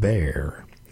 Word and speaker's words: Bere: It's called Bear. That's spelled B-E-R-E, and bear is a type of barley grown in the Orkney Isles.